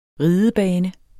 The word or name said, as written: ridebane